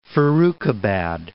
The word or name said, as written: Farrukhabad